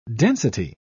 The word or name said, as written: density